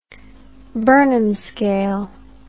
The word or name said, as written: Burnham scale